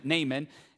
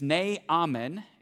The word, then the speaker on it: Naaman: I've always called him Naaman. And as I was getting ready, some of my team members gave me a more accurate pronunciation of his name. It's Naaman.